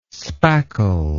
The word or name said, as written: spackle